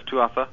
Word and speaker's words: Tuatha: If you ever get an opportunity to write me back on this, is that you have mentioned in your book the Tuatha. Am I pronouncing that right? The Tuatha? – Oh, yeah. The English uh-huh. pronunciation is Tuatha.